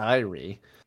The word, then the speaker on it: Ire: And I'm going to go with I-R-E. Just simple, short. – You are correct. And okay. – Not on. – Elliot is not to be faulted that everyone would assume that would be pronounced ire. But no, yeah. It's I-R-E, like two syllables.